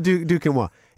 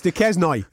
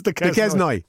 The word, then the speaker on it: Duquesnoy: No, it's not Duquenoir. It's Duquesnoy, right? – It's Duquesnoy. Absolutely.